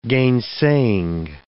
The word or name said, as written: gainsaying